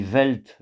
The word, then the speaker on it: Welt: Die Welt, j'attire l'attention sur le fait que le W se prononce toujours V. Die Welt, il n'y a pas de son W en allemand.